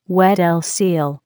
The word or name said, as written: Weddell seal